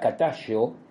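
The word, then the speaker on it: Katazho: Hoy vamos a hablar de los petroglifos de Catallo, así como lo pronuncian, este término K-A-T-A-Z-H-O-S y que sin embargo los locales eh, lo pronuncian como s h o eh, Y, ¿no? Y, Catayo, eh, que se encuentra en la provincia de Morona, Santiago.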